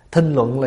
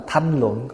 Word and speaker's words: thinh luận: Thinh luận là gì? Có chỗ đọc là thanh luận, có chỗ đọc là thinh luận.